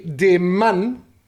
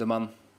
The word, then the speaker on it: de Mann: Olivier de Mann. Wie er heißt? Ich glaub's dir übrigens mittlerweile. – Mein last name is pronounced de Mann. De Mann. De Mann, yeah.